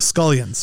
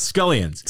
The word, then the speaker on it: scullions: Scullions. – Scullions. – So think scallions, but with skull.